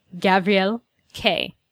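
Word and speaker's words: Guy Gavriel Kay: Guy Gavriel K.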